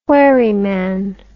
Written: wherryman

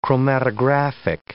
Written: chromatographic

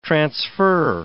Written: transfer

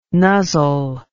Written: nuzzle

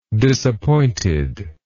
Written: disappointedly

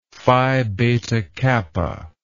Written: Phi Beta Kappa

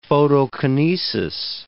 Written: photokinesis

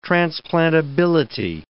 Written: transplantability

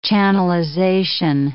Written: channelization

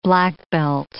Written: black belt

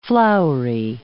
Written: floury

